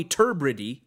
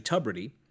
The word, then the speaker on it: Tubridy: Tommy Turbridy, he says, but it's actually Tubridy.